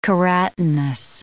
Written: keratinous

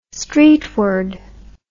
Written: streetward

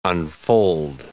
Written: unfold

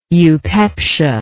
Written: eupeptically

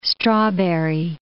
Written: strawberry

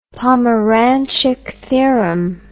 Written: pomeranchuck theorem

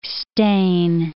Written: stain

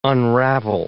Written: unravel

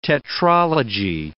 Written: tetralogy

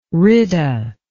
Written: rita

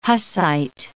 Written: Hussite

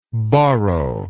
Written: Borrovian

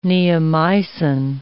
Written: neomycin

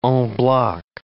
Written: en bloc

